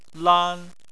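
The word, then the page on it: lan: tone 1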